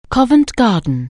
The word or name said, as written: Covent Garden